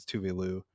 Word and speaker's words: Tove Lo: but now everybody knows that the correct pronunciation is Tuvalu.